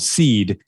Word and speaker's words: C3D3: And then I have an electronic project called Seed. – Okay. – And it's spelled C3D3.